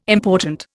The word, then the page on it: important: Indian